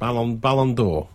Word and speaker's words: Ballon d'Or: Or how do you say it? – Ballon d'Or. Yeah.